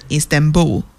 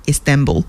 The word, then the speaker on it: Istanbul: I met in Istanbul, 或者是 Istanbul, right? 哎，这两个重音其实都可以 Istanbul, right? Pretty British accent. And for American accent, or like, you know, one way of American accent, we can pronounce this word with、 Istanbul.